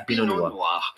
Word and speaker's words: Pinot Noir: Pinot Noir.